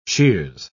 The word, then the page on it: sheers: British English